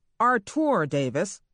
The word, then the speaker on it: Artur: Artur Davis, pronounced that way, as his mother, a French teacher, intended, was born in Montgomery in 1967, well after the worst racial strife in his native city had played itself out.